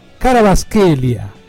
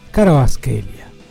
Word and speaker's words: Kvaratskhelia: Salió bien, ¿no? Kvaratskhelia. Ahora, si ustedes lo ven escrito, es muy difícil. Pero creo que me defendí bastante bien. Porque se escribe con Kvaratskhelia. Pero yo le voy a decir que creo que está bien dicho.